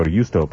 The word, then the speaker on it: Giusto: And also was instrumental in rescuing a man named Prince Giusto Valerio Borghese, or Giusto probably, to give it an Italian pronunciation.